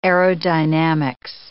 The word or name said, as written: aerodynamics